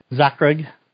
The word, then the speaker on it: Zakreg: Zakreg. – Zakreg? – Zakreg, yep. – Z-A-K-R-E-G? – G, yep.